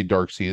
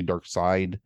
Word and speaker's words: Darkseid: I always say Darkseid. It's actually Darkseid. I say it wrong all the time.